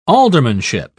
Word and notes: aldermanship: British English

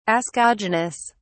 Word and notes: ascogenous: British English

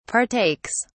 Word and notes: partakes: British English

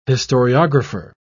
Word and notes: historiographer: British English

